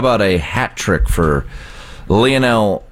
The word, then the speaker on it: Lionel: Okay, do that pronunciation again for me, because I have heard it about 8 different ways. – I believe it's Lionel. – Okay. – Or you could just go Lionel.